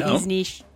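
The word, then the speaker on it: Višnjić: Timeless's Goran Visnik. Am I saying that right? – Nope. Visnish.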